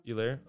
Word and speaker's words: Helaire: It's Helaire. – Helaire? Oh. – Helaire. – Oh, my bad. – H is silent, bro.